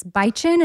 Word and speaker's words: Bitchin': Bitchin' sauce, which my son, whenever he's looking at the jar, he's like, what is this, bichin? And I'm like, yep. – Yep. – That is exactly how you say it.